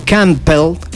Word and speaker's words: Campbell: El físico Tom Campbell, se escribe C-A-M-P-B-E-L-L.